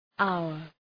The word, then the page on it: hour: ‘aʋər